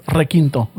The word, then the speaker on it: requinto: But it's like right now when I was asking Esteban, hey, ¿qué es renquinto? It's requinto. – Requinto, yeah.